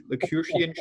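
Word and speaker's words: Lucasian: The Lacurtian chair. I'm not pronouncing that right. But Stephen Hawking had that chair. – It sounds right.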